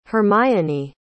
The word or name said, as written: Hermione